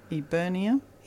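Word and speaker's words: Eburnea: E-B-U. – E-B-U. – R-N-E-A. Eubernia? – Eubernia.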